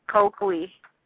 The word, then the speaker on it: Coakley: If you Google it, it'll say coin, like two coins, but the proper pronunciation is Marie Therese Coakley.